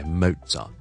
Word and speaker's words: Mozart: OK，跟住我哋到莫扎特，我哋通常咧英文咧发音系 Mozart。